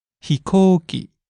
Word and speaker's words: ひこうき: There's a long O vowel in there. In the middle, you want to hold it. ひこうき。